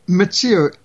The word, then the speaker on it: Mitsuo: Mitsuo, M-I-T-S-U-O, Mitsuo Fuchida, F-U-C-H-I-D-A.